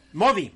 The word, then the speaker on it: Modi: Diferente es el señor Mori, que es el, el gobernante de la India, creo que pronuncié bien su nombre, Modi, Modi, el señor Modi, que sí lo ha usado desde el principio.